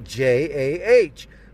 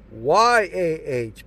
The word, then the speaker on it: Jah: But in the King James Version, it's spelled J A H, but it's pronounced Y A H because that's Yah's name. It's pronounced phonetically Y A H, Yah, not Jah.